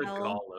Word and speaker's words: Gaul: The biggest campaign that he does after this is the invasion of Gaul, with G-A-U-L. – The Gaul of him. – Gaul. Again, I'm not doing this pronunciation very well. – No, Gaul's right.